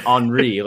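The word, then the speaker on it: Henry: Onyekuru pronounces his name Henri, like Thierry Henri.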